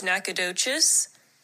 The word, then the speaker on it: Nacogdoches: And here is the correct pronunciation of the five. So one is Green, two is Bernie, three is Nacogdoches, four is Waxahachie, and five is Pflugerville.